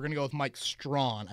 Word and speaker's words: Strachan: I think it's actually, and again, I asked for these pronunciations because this one will confuse the hell out of me. Strachan is what I was saying. Strachan, okay. – Strachan, like I think we're taking out the CH. – Oh, just Strachan. – Yeah, that's what I was told.